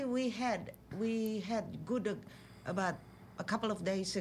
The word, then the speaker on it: Gudeg: Gudeg? – Gudeg.